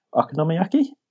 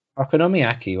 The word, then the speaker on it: Okonomiyaki: Um, I'm, I'm going to say the name wrong again, but I'm Namiyakis." Uh, Okonomiyaki. – Okonomiyaki, well done.